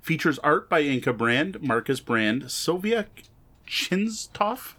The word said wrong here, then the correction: Christoph: Isn't that Christoph? – Christoph, yes it would be. Sylvia Christoph, thank you.